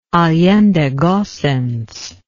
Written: allende gossens

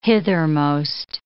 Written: hithermost

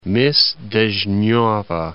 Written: Mys Dezhneva

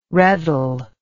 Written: reddle

